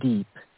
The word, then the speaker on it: Deep: It is Deep, as in deep water.